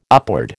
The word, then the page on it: upward: American English